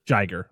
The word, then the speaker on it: Jiger: So, yeah, this is when the second kaiju is it Jai, how do you pronounce this one? – Jiger. – Okay.